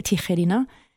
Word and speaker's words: Tijerina: En fait, Tijerina, ça se prononce en espagnol, Tijerina, et c'est le nom de famille d'une famille d'origine mexicaine-américaine.